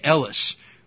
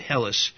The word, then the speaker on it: Hellas: Now the Greeks call their area Elis or Hellas. They don't have the H sound in Greek. We pronounce it Hellas in English.